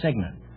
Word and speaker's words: cygnet: No, it's signet. It's spelled C-Y-G-N-E-T, but it's pronounced signet.